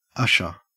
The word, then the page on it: usher: Australian English